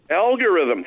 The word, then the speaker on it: algorithm: A logarithm. What? How do you say that word? – Algorithm. – Algorithm. Thank you.